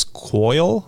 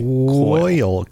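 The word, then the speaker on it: Quoyle: A Drell standing nearby, the Drell's name is Coyle, I think is how you say it. It's Q-U-O-Y-L-E. I would say Coyle. – Coyle.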